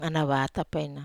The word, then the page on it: ana vata: Wamesa